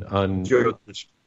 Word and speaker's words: Jyotish: But one of the things after having the experience that I did have with you, where you did Jyotish on Jyotish.